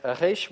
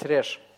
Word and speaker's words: реш: Так что хоть так вы можете произносить, это не будет считаться ошибкой, вы можете говорить «рещ», можете говорить «реш».